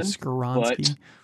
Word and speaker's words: Skoronski: So, all right, I'm going with pick 13 in the Jets, and I'm going to go offensive lineman again with Peter Skoronski. – Okay. – I probably butchered his name again. – Skoronski.